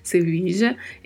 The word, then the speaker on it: Sevilla: Sevilla é a capital da Andalucia, que fica no sul da Espanha, e temos duas particularidades de pronúncia aí nesta palavra. Primeiro, a letra V com leve som de B e o duplo L, que já vimos em outros podcasts que tem pronúncias variadas a depender das regiões.